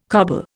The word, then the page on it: coble: Indian